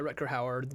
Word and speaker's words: Rutger Hauer: But when Deckard and kind of the main villain of this movie, played by Rutger Hauer, is that how you say it? Yeah, Rutger Hauer.